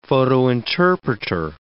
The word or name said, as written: photointerpreter